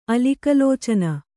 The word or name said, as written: ಅಲಿಕಲೋಚನ